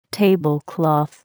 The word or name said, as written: tablecloth